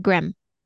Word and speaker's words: Graeme: I don't know if you'd say Graham Simsion, but it's spelled Graeme. – Oh yeah, so it's Scottish. So it's like Graham. – Graham, there you go.